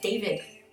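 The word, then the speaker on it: d4vd: It's spelled D, the number 4, VD. – Dead. – So I've never actually said any, like, it's like, oh, this guy. I just know. So it says in the thing about the artist pronounced David.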